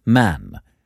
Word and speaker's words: Mahon: He had a Roman nose, a snow-white, long beard, and his name was Mahon, but he insisted that it should be pronounced Man.